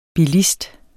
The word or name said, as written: BILISTER